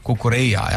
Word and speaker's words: Cucurella: Cucurella jah ,